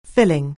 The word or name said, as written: filling